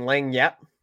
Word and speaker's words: Lagniappe: Lagniappe.